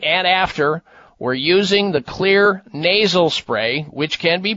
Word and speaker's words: Xlear: These are all places where you can purchase clear nasal spray. And clear is spelled with an X. The X in the word clear, X-L-E-A-R,